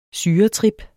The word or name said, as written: syretrip